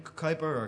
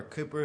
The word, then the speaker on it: Cowper: That's why William Cowper, or Cooper as it should be pronounced,